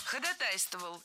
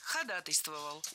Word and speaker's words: ходатайствовал: Ходатайствовал. Неверно. Правильный вариант – ходатайствовал. Ударение в этом слове падает на первую «а» в суффиксе «атой». Кстати, во всех словах, где есть такой суффикс, ударение точно так же падает на «атой».